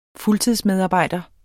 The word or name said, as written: fuldtidsmedarbejder